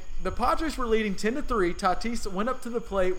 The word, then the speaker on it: Tatis: The one in particular is Fernando Tatis Jr. Is that right, Tony? – Tatis, I believe, is how they say it.